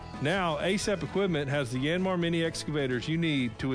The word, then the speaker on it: Yanmar: You've seen them outperform other mini excavators on the job site, and you've wondered how to pronounce its funny name. By the way, it's Yanmar, and ASAP Equipment is your only local Yanmar dealer.